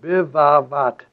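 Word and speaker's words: Bivavat (בבבת): And without the point, it's, we pronounce it then a V. So Bivavat.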